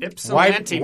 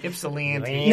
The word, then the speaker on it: Ypsilanti: Ypsilanti, Michigan. – You got to do Midwest with the land. – Okay. – Ypsilanti. – Now, I'm sure in 1959 it was the Ypsilanti Center for the Mentally Disturbed or sanitarium or some horrible name for the asylum that they had these three schizophrenic patients at.